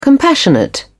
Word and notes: compassionate: British English

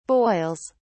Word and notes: boils: British English

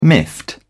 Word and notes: miffed: British English

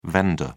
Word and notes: vender: British English

